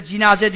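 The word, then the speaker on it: cinaze (جنازة): Mevla buyuruyor ki min sa'aten yuza'al meyyitu alel cenazeti. Ölü cenaze üzerine, yani serir üzerine, buradaki cenazeden maksat cinaze, Arapçada cinaze diye okunuyor, alel cinazeti.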